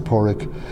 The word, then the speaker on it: Padraig: It's P-A-D-R-A-I-G. You don't pronounce the D. Basically, it's Porik. You can pronounce the D or not, as you wish, and then some more on.